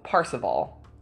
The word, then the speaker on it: Parzival: But in the book, it's spelled Parzival, so Parsival is what I'm going with.